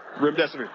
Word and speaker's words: Remdesivir: I actually pronounce it, your death is near. Remdesivir. If that helps you remember.